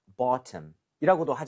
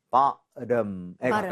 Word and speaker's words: bottom: 이거는 사실 발음이 약간 영국식으로 간다면은 bottom이라고도 하지만 우리가 북미식으로 미국식으로 하면은 보통 여기 flap을 내요. 그래서 여기 강세를 주고 ba 하고 바. 여기가 리을 비슷하게 가기 때문에 bottom에 가깝습니다.